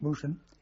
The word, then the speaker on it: Mosjøen: called Mosjøen, M-O-S-J-O-E-N, pronounced Mosjøen, where there were some French troops, Chasseurs Alpins.